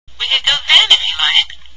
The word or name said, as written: if you like